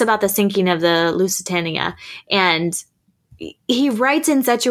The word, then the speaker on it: Lusitania: How do you say it? – The Lusitania, yeah. – Lusitania, yeah.